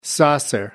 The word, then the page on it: saucer: American English